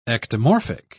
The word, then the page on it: ectomorphic: British English